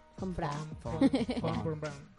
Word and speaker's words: von Braun: Fun Brown. – Como si fuera fun de divertido.